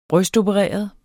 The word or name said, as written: brystopereret